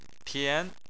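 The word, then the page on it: tian: tone 2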